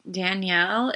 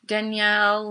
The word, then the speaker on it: Dynel: Okay, so she went to the home of, I think it's Danielle. It's spelled weird. It's D-Y-N-E-L. – Mm-hmm. – Danielle Lane.